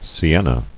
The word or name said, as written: Sienna